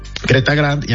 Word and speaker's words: Creta Grand: Empieza en 451,000 pesos, y está, recuerden que está la Creta y la Gran Creta, y además tienen, o bueno, Creta Gran, para decir bien el nombre como se escribe. Creta Grand, y además traen los motores turbo.